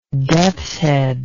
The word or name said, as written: deathshead